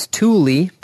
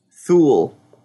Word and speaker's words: Thule: It's Thule, but it's spelled T-H-U-L-E. – Thule. – No, it's Thule. It's Swedish.